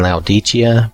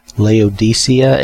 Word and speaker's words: Laodicea: Now, I was saying Laodicea, but from what I've seen and listened to and read, the pronunciation should probably be Laodicea. At least that's the accepted pronunciation from what I can tell.